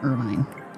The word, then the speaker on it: Irvine: So on all the TV shows that he's on, they pronounce his last name Irvine.